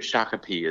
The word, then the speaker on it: Shakopee: Maybe I should be, but I'm pacing the halls of I think it's a Baymont Inn in Shakopee is how you pronounce it. Shakopee, Minnesota, about to, well, potentially participate in this Deer and Turkey Expo here.